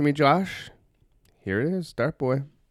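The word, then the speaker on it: Dartboi: is provided by, I don't know how to pronounce this properly, Dart Boy Music. Well, that was pretty easy, actually. It's D-A-R-T-B-O-I Music.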